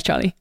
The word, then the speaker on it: Charlie: Well, hi, I'm Charlie. I don't actually say my name like that. My name is Charlie.